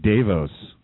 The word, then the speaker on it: DeVos: DeVos. DeVos. – Devos. – Yep. – All right. Like Davos. Davos.